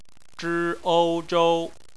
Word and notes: zhou: bpmf

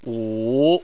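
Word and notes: wu: wu3; tone 3

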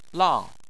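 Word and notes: lang: tone 4

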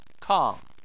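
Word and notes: kang: tone 4

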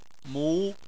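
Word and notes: mu2: tone 2

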